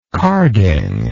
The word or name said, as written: carding